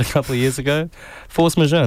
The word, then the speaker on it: Force Majeure: Yeah, Force Majeure.